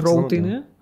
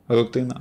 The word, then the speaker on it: рутина: Чого ти кажеш роутиною? Рутина.